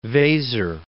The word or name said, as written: Weser